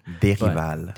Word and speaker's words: Derival: It's a French name, but I'm not of that origin. – Derival.